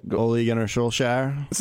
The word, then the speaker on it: Ole Gunnar Solskjaer: Ole Gunnar Solskjaer.